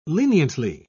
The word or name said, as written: leniently